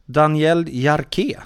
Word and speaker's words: Daniel Jarque: Daniel Jarque, kan det vara det? – Ja, det, just det.